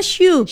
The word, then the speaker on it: choux: Kirain Cox dibacanya, tapi ternyata Shoe.